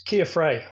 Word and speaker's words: Keir Frei: And I see, I'm really sorry, I'm not going to say this right, Keir Frey. Is that right? – Pretty close. Keir Frey.